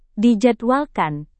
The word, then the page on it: dijadwalkan: indonesian